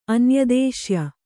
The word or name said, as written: ಅನ್ಯದೇಶ್ಯ